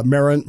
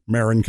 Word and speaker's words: Marin: Uh, or well residents, I guess, and uh, Marin Marin County, Marin County. See, that was a real mispronunciation.